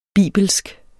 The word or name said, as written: bibelsk